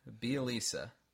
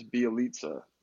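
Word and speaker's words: Bjelica: Bielisa. – Is it a soft C? The way that I've heard it is Bielitsa. Like almost like a pizza.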